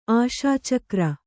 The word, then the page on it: ಆಶಾಚಕ್ರ: Kannada